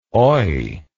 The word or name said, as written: oi